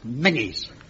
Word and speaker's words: Menzies: It's pronounced Menzies.